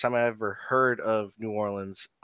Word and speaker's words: New Orleans: So question, how do you say New Orleans? – I say it kind of like you do, like just New Orleans. – Okay, because people are like, nah, it's Nolens, and there's five different...